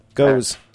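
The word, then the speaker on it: Gose: G-O-Z-E. – G-O-Z-E. – Yeah. Goes. Like steady as she goes.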